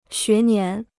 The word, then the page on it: 学年: xué nián